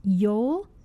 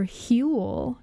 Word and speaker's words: Jul: Jule, or J-U-L, I'm not sure the correct pronunciation. – What is it? – Jule, J-U-L. – It is Yule or Huel is what I pronounce.